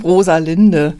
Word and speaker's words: Rosalind: Aber ich könnte jetzt auch nicht sagen, also Rosalind, es könnte auch deutsch sein, Rosalinde. – Ja, ja. – Naja, gut. – Sie hat sich übrigens praktisch ohne das A ausgesprochen, also Rosalind, kurze Form,